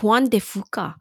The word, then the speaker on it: Juan de Fuca: It's the Strait of Juan de Fuca. The thing I cannot pronounce. Unless I am saying it right, then that's right. – A miracle. – Yeah, that sounds right.